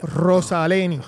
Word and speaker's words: Rosaleny: Estamos hablando de Soy Motor, un proyecto de Antonio Lobato y Cristóbal Rose, eh, ¿cómo se dice? Rosaleni. Rosaleni.